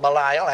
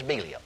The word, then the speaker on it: Belial: We call it Belial. Actually, the correct pronunciation is Belial.